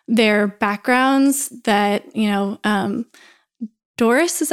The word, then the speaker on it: Dorys: But then I Googled it at some point and I was like, how to pronounce D-O-R-Y-S. And at least the internet told me that you just pronounce it Doris.